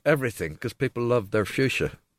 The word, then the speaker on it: fuchsia: So fuchsia, as everyone calls it, those lovely hanging basket plants that everybody wants, should be pronounced Fuchsia because it was named after German botanist Leonard Fuchs.